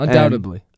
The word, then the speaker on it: undoubtedly: I wouldn't pronounce the B, but it wouldn't be like a D. It wouldn't be like a hard D. You undoubtedly.